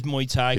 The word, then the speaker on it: Muay Thai: It's pronounced as Muay Thai.